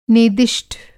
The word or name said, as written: नेदिष्ठ